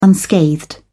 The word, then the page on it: unscathed: British English